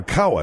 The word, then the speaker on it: Cowa: And joining us now from Lincoln's in Laguna Beach is Ken Gawa, Mr. Gawa, or Kawa, excuse me, C-O-W-A. – C-O-W-A is one way that the family name is spelled it, Phil.